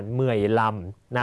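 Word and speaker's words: mười lăm: เหมื่อยลำนะครับสังเกตเลข 5 ถ้าเลข 5อยู่โดดๆจะใช้คำว่านำแต่ถ้าอยู่ข้างออกเสียงว่าลำนะ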